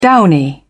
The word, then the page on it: downy: British English